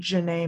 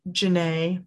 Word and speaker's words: Jenay: And then personal Instagram is Jazzy Janae May at Jazzy Janae May, M-A-Y. That's my middle name. – And Janae is spelled J-E-N-A-Y, right? – Yes.